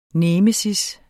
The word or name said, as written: nemesis